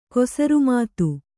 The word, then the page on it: ಕುಸುರಿಮಾತು: kusuri mātu; kannada